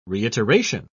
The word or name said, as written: reiteration